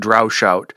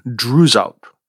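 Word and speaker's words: Droeshout: I think I've been pronouncing it Drowshout. And I just checked with the Folger Shakespeare Library, and I think the more correct pronunciation should be Drewsout.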